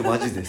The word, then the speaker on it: 本気: 本気と書いてマジです。はい。